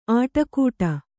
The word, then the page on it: ಆಟಕೂಟ: āṭakūṭa